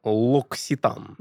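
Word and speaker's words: L'Occitane: Нет, мне вот а, французская сеть косметики Локситан. – Ну оно так и читается, в принципе.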